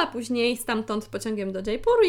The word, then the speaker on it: Jaipur: i stamtąd do Jaipuru, nie Jaipuru, chociaż no dobra, niektórzy tak mówią i też myślę, że z tego co pamiętam jest spór pomiędzy wydziałami w różnych miastach w Polsce ogólnie i są zwolennicy właśnie takiej wymowy dż, ale ona nie jest po prostu poprawna najzwyczajniej w świecie ze względu na to,